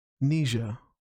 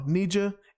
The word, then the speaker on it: Nija: Now, for me, I got this artist named Nija. I believe that's how you say her name, N-I-J-A.